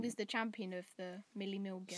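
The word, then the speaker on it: Mille Miglia: How do you pronounce it? The Milli Miglia. – Milli Miglia.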